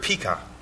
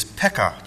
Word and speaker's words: Pekah: Congratulate John on pronouncing that correctly. Usually you hear it Pekah. It is Pekah, just the way he pronounced it.